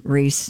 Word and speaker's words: Rhys: Does he pronounce it Reese? – Reese. – Does he? I don't know. – Oh, it is Reese.